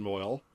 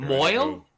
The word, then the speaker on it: Moyle: Alan Moyle is the name of the actor. – Moyle? – Yeah. – Moyle? – Yeah. – Like the guy who does the circumcision, Moyle? – No, it's spelled M-O-Y-L-E.